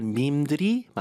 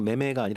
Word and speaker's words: meme: 스펠링이 좀 특이해요. M-E-M-E 이건데 네. 읽을 때는 그냥 매매가 아니라 밈으로 읽어요.